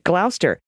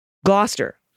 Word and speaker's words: Gloucester: All right, Gloucester. Gloucester. Dr. Foster Gloucester.